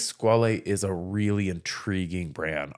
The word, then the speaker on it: Squale: Squale. – Ooh-hoo. We said it right. – Squale. We did say it right.